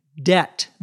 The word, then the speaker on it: debt: Your credit, C-R-E-D-I-T, history, is a list of all of the loans and debt that you have. Debt, D-E-B-T, notice we don't pronounce the B, is money you owe other people.